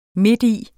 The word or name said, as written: midti